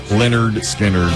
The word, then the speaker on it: Lynyrd Skynyrd: 53 years ago today, Lynyrd Skynyrd releases this album with Freebird on it, pronounced Lynyrd Skynyrd.